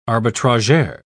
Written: arbitrageur

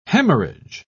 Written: hemorrhage

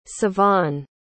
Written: sivan

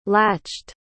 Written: latched

